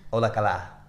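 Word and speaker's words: Hola cala (όλα καλά): And we learned that hola calo. I'm saying it wrong. – Yeah. – Hola cala.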